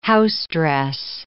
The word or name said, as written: housedress